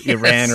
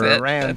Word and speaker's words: Iran: It's, between 130 and 350 tons of oil, depending on your Iran or Iran. – That's right. British pronunciation.